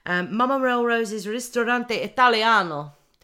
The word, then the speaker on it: Mama Melrose's Ristorante Italiano: Mama Melrose's Ristorante Italiano. Did I say that right? – You did say it right.